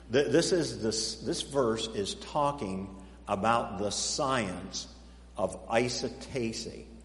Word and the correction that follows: isostasy: Now, I didn't say that right. Isosceles triangle. Here, let me spell it for you. I-S-O-S. Isostasy. Isostasy. There it is. That's the word. This is the science of isostasy. I-S-O-S-T-A-S-Y.